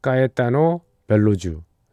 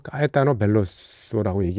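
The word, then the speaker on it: Caetano Veloso: 어, 브라질의 싱어송라이터이자 예, 기타도 치고 노래 부르는 예, 예, 까에타노 벨로주 저는 뭐 까에타노 벨로소라고 얘기를 하는데 예, 본디 발음은 까에타노 벨로주가 맞는 것 같습니다.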